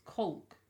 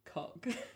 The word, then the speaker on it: caulk: they have American accents, and we as English people pronounce this "colk." Yeah. C a u l k "colk," and they pronounce it "cock."